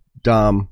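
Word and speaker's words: Domme: Well, Dom A is a female Dom. Dominatrix usually means a pro. But, you know, Dom A, it's actually pronounced Dom the same as a male, but it's D-O-M-M-E.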